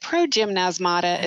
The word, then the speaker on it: progymnasmata: is it progymnasmata? Is that how you pronounce that word? – Nasmata. That is so hard. Yes, that's it.